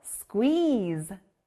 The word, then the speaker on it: squeeze: 퀘 소리 나는 다음 단어 배워볼게요. 바로 꼭 짜다, squeeze예요. 여기는 중간에 퀘 소리 들렸죠? 우리가 과일 주스를 해 먹으려면 과일을 꼭 짜야 돼요. 짜다, 영어로 squeeze.